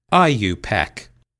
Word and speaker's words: IUPAC: For now, just know that chemists call it by its acronym, IUPAC, pronounced IUPAC. The following year,